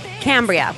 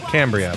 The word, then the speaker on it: Cambria: What is the right way to say it? – Cambria. – Cambria.